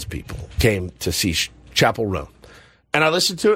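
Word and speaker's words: Chappell: Tyler wants to know my take on, well, how do you say her name? Chappelle Roan? – Chappelle Roan.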